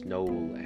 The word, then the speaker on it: Noel: Yeah, Douglas Noel Adams, I believe is how you pronounce his middle name.